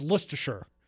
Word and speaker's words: Leicestershire: Well, this actually happened in landlocked Leicestershire. Leicester. I don't know how to say it. Do I look like I know anywhere over there? – I think it's Leicestershire. If I know it, Leicestershire.